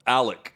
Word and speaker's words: Alex: Alex Murdaugh, but it's Alec is what everyone says.